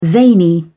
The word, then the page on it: zany: British English